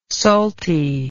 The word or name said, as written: saltie